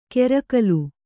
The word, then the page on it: ಕೆರಕಲು: kerakalu